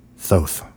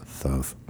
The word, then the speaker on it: Thoth: Thoth. Thoth. That's how they say it in the movie. That's how Karloff says it with that lisp. Thoth.